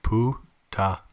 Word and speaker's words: puta: Puta? – Yeah, apparently. – P-U-T-A? – Yeah, pronounced poo-ta.